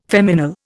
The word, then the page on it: feminal: Indian